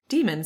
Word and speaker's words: daemon: And there is a note in the beginning of the book that says demon, spelled D-A-E-M-O-N, and the little A and the E all smushed together, is pronounced like the word demon in English.